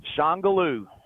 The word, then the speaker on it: Shongaloo: How do you pronounce it again? – Shongaloo. – It's the French pronunciation of Shangala. – Yes. – From the old song.